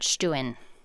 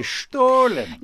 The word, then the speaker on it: Stollen: So my family pronounces it Stuen. – Oh my gosh, I was going to say Stollen. – Yes, that's the High German pronunciation. – Oh, all right. – We're Low German.